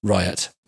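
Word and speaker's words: Riut: It's R-I-U-T, but that is pronounced Riot.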